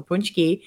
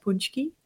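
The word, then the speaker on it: pączki: It still does to practice how to actually say it because it looks so different than the way you spell it. But punchki.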